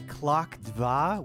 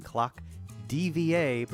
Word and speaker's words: Klok Dva: Before that, we heard North Loop by Klok Dva, which the entire time I've known of that band, I was mistakenly pronouncing Klok DVA, but no, it's the Russian word for the number two, Klok Dva, post-punk group in the 80s and did industrial stuff.